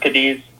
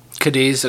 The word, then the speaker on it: Cadiz: I believe it's pronounced Cadiz. – Cadiz, okay.